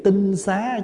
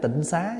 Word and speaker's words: tinh xá: Đại chúng để ý cái chữ tinh xá chứ không phải tịnh xá nha. Nếu mình đọc đúng chữ là tinh xá, tại vì chữ tinh là gì? Tức là tinh khiết, là trong sạch. Xá là nhà, cái nhà đó là nhà trong sạch tinh khiết.